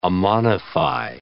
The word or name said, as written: ammonify